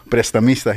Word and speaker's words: Prestamista: In fact, on LinkedIn, your title is, and I want to make sure I'm pronouncing this correctly, so Prestamista. – Prestamista, yes. – I did okay? – Yes, it did. Yes, you did.